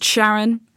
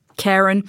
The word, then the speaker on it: Charon: But there was also something very strange they saw on Charon, which is sometimes pronounced Charon, sometimes pronounced Charon, which is the largest moon of Pluto, which is almost the same size as it.